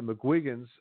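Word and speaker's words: McGuigan: Uh, and it's good to see that uh, the McGuins and uh, uh, the McGuigans, sorry, let me not uh, butcher that, the McGuigans uh, Cyclone Promotions uh, you know, they've pushed Taylor, and I know Taylor's not young.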